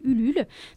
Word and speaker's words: Ulule: Oui, par ailleurs, on a déposé notre dossier, notre projet, sur un site participatif de fonds européens qui s'appelle Ulule. Donc ça s'écrit ULULE.